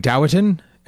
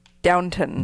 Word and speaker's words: Downton: See, I've been saying Doughton, and that's not correct. – Nope. – Doughton. Doughton. – I'm doubting that's correct. – Is it? Boo. – Get out. – How do you say it? – Downton.